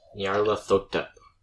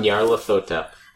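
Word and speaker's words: Nyarlathotep: Nyarla Thotep. – I'm gonna have to spell that. Say it one more time, though. – Nyarla Thotep.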